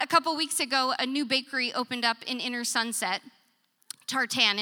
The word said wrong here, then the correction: Tartine: And am I even saying it right? Tartine. I have the cookbook. You think I'd know. Thank you. Now we all know. Go to Tartine.